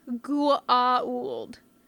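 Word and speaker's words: Goa'uld: Three syllables. Gua'uld. With an A in the middle.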